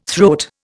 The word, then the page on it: throat: Indian